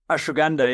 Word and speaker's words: ashwagandha: We were hearing a lot about, I'm going to say this wrong, probably ashwagandha. – Ashwagandha, yeah.